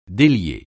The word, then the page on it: délier: français